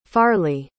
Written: farly

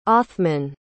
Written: othman

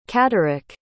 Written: catterick

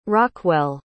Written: rockwell

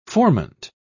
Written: formant

